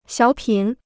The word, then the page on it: 小品: xiǎo pǐn